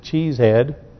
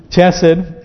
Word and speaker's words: Chesed: or as Brother Reggie used to pronounce this, Cheesehead, Chesed, and Hazo, and Pildash, and Jidlaf, and Bethuel.